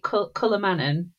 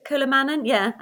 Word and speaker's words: Kullamannen: So that brings us nicely, doesn't it, to the, now am I pronouncing this correctly, is it Kulamannen? – Kulamannen, I think that's it.